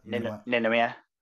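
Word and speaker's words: Ninomiya: How do you pronounce his name? Ninomaya? – Ninomaya. Gotta say it fast.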